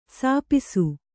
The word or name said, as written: ಸಾಪಿಸು